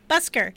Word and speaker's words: Busker: Did I pronounce it wrong? – Busker, what did I say? – Busker. – Oh. – Like, you know, in the subways. – Oh, yeah, like a street musician.